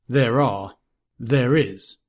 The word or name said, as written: there are